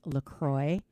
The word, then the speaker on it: LaCroix: Turns out it is pronounced LaCroix because it was made in, it's the River LaCroix in Wisconsin.